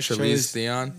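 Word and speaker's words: Charlize Theron: But Charlize Theon. Am I saying it right? Charlize Theon. – Yeah.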